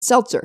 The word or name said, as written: seltzer